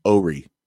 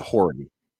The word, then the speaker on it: Horry: There's also a county, it's spelled Horry, but it's pronounced Ory. – Yeah. – Don't call it Horry.